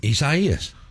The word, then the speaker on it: Isaías: Isaiah in Spanish. – Well, pronounce it for me. – Isaías. – Okay. Sounds great.